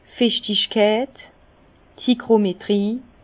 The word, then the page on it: d' Fichtigkeit, d' Hygrometrie: Alsatian; Bas-Rhin; Herrlisheim